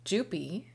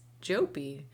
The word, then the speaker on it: Jouppi: We all say Jouppi, but apparently the proper Americanization of his name is Jouppi. And so, breaking news, folks, you heard it here first. It's Norm Jouppi.